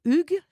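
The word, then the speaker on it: hygge: Je crois que l'une de vos recettes, c'est le, alors moi je vais dire le HUG, je crois que c'est comme ça qu'on dit, mais ça s'écrit I-H-Y-G-G-E, hein, c'est ça? – Oui.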